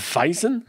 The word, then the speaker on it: Weizen: Chez les Allemands, la Weizen, c'est W, mais euh, le langage euh, allemand, je pense qu'on prononce comme un V, donc Weizen.